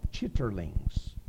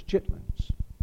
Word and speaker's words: chitlins: Looks like it ought to be pronounced chitterlings. But it is not pronounced that way. It's chitlins.